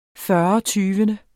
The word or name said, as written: fyrretyvende